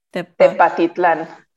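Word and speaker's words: Tepatitlán: Tepatitlan. – Tepatitlan.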